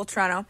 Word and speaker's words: Toronto: Well, I'm happy to hear, and it sounds like you're happy to be in Toronto, where Toronto. I have to say it like a local, Toronto.